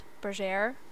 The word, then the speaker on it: Berger: my name is Charlotte Berger, pronounced burger, like the food.